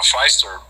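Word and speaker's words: Pfister: Now, Feister, it's a Silent P.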